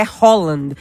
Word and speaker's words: Haaland: Ele mesmo disse, né, que a pronúncia é Haaland.